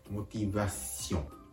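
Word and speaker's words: motivation: ポイントとしては、んを発音する寸前で鼻から息を抜くように、むしろ鼻からんと発音するように発音するところがポイントです。モティバシオン、モティバシオン。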